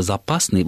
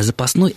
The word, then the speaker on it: запасный: Дело в том, что слово «запасный» вот с таким ударением на основе – это книжная форма, которая восходит к старославянским и древнерусской письменной речи. А «запасной» – это разговорная форма.